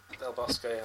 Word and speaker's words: Del Bosque: I really can't pronounce it, but Del Bostiki. – Del Bosque, oh, yeah, I accept that.